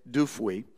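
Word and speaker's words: Dupuit: I looked up the pronunciation of his last name. It's Dufuy. He wrote an article introducing a concept that might be familiar with some of you.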